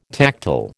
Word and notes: tactile: American English

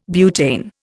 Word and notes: butane: Indian

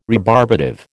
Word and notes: rebarbative: American English

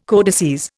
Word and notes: codices: Indian

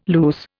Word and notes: loose: Indian

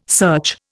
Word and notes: search: Indian

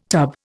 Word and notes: tub: Indian

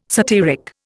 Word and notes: satyric: Indian

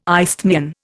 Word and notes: isthmian: Indian

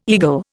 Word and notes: eagle: Indian

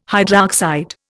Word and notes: hydroxide: Indian

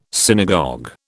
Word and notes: synagogue: British English

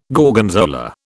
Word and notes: Gorgonzola: British English

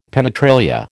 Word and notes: penetralia: American English

